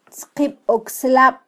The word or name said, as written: tzqibokslab